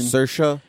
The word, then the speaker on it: Saoirse: Saoirse Ronan. – No, that's not how it's spelled. – But that's how you say it.